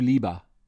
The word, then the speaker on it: liebe: Make sure you make the difference between ich liebe, I love, and ich lebe, I live or reside.